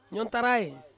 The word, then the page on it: nyontarai: Ansus